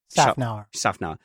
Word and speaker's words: Szafnauer: I'm going to give my Missed Apex Award to Otmar Szafnauer. Am I saying that right? Otmar Szafnauer.